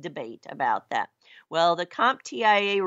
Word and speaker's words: CompTIA: Now, in September of 2020, this year, CompTIA, or CompTIA,